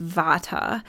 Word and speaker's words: Vata: So one is pronounced Vata, and that is a person who has primarily a predominance of ether air qualities.